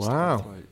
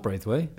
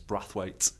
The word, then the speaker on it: Braithwaite: There should be no I in Brathwaite. So it's pronounced Brathwaite. – Yes. So it's Brad. It's not Braithwaite. – It's Brathwaite, yes.